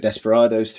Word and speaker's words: Desperados: Unfortunately, that I hope that pronunciation has not ruined this game for everybody as it's ruined it for me. Um, it, we're talking about Desperados 3.